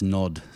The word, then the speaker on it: Gnod: That's Nod, spelt with a silent G at the beginning. I think it must be silent, otherwise it's Gnod.